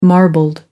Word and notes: marbled: American English

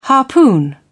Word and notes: harpoon: British English

